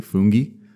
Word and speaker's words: fungi: I believe the correct pronunciation is actually fungi, but no one walks around and says fungi. I think even in the academic community, fungi or fungi are both acceptable.